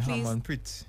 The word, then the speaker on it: Harmanpreet: Yeah, Harmanpreet.